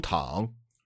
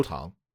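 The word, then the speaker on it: Tang (汤): Did you pronounce tongue as the second tongue? – Yes. – Oh, no, I'm sorry. It's the third tongue. – Oh, Ge You Tang.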